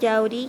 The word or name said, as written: Yauri